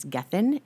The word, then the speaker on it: Gethin: Gethin Anthony, and I think I'm saying that right. I think it's Gethin. It's G-E-T-H-I-N.